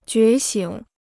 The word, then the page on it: 觉醒: jué xǐng